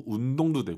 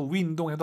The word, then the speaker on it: 运动: 자, 발음하실 때 입을 가로로 벌려서 윈, 우리가 흔히 알고 있는 그 영어의 WIN, 윈할때그윈 발음이 아니에요. 사실은 운동은 어렵게 발음하셔야 돼요. 운동이 어렵잖아요. YU 발음은 입술을 동그랗게 말아서 으 운동 이거예요.